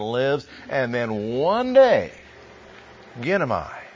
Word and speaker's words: ginomai: It's the word ginomai. For transliteration, G-I-N-O-M-A-I.